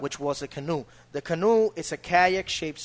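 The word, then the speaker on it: Kanú: But well, written in a Spanish way, K-A-N-U with an accent on the U. – Yeah, with a little... – Exactly. Yeah. So you pronounce Kanu. – Kanu. – Kanu. – Yeah. It almost sounds like Cuernu.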